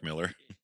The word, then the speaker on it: Millar: It's like Mark Millar, but apparently it's Mark Miller. – Yeah. Yeah, exactly. It's like, why is there two L's and an AR? That's, come on. – Yeah. – Miller's an ER, dude.